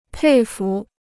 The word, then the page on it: 佩服: pèi fú